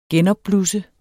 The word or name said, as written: genopblusse